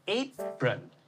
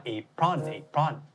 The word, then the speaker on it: apron: apron ถูกไม่ได้ออกเสียงว่า apron